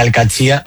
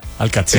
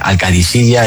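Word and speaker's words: Al-Qadsiah: Bueno, se suele decir Al-Katsia. – Al-Katsia. – Pero también hay gente que dice Al-Kadisiya. Es que es difícil, ¿eh? Ah. No es fácil, pero Al-Katsia es lo más fácil.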